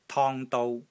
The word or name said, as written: TONG1 DOU1